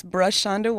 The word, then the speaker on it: Brashanda: My name is Brush Shonda Walker.